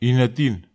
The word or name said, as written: inatin